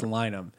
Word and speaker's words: Lynam: Like shame on us for not even realizing Elgin, how much this tournament, how much Big Mike, like Jim Lyman, or Lynam. I said, that's what I was saying it wrong. It's Lynam. – It's Lynam. – I probably said Lyman in the past. It's okay. Lynam.